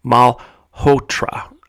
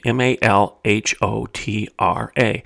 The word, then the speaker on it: Malhotra: They even featured recently a cardiologist by the name of Aseem Malhotra. I think that's how you pronounce his last name M A L H O T R A.